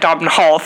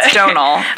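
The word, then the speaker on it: Domhnall: Donal? Yeah, Donal. Yeah. – I feel like a fool. – I know, I had said Dobbin Hall for a while as well. – But, it's Donal.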